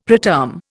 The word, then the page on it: preterm: Indian